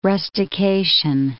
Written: rustication

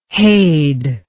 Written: hade

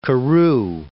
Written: Carew

